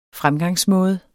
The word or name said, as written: fremgangsmåde